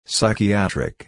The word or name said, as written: psychiatric